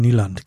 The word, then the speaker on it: Nyland: Also damals, als wir so den Björn Nyland, genau. Oder Christoph, wie spricht man das aus, wenn NY-Land auf Norwegisch, vermutlich? – Ja, das Problematische ist das Y, der Y-Laut, der irgendwo zwischen I und Ü ist.